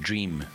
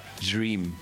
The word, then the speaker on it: Jream: But they spell it with a J. – Wat? – Ja, dream. – Waar is die? – Not dream, dream. So it's a J. – Spel hem? – J-R-E-A-M. Like dream, maar net dream.